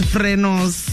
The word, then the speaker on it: frenos: The right way to say it, apparently, is frenos.